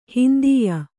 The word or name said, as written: ಹಿಂದೀಯ